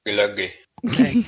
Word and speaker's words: Glögi: Glögi.